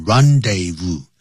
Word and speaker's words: rendezvous: The Moulin Rouge was the rendezvous, 念成 rendezvous, du high life.